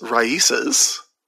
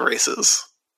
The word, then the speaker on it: RAICES: Also, I do want to point out that I have been informed that it's Raices, not Races, the name of the charity down in Texas, that R-A-I-C-E-S, and I did mispronounce that last week.